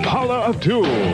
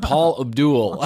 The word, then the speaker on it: Paula Abdul: She was in on the party line, but he can't even say her name right. He said Paul Abdul.